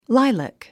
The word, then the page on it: lilac: láilək; ライラク